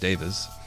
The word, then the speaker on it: Davies: So the Kinks primarily consisted of the brothers Ray and Dave Davis, spelled like Davies, but according to Ray, it's pronounced Davis, with Ray handling most of the songwriting duties.